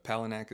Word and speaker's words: Palahniuk: So it's uh, Palahniuk is how you pronounce his.